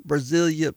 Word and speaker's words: Brazoria: And then I hope I say this right. The Brasilia County. Is that right? How you say that? Brasilia, Brazoria County has more species of birds than any other area in North America.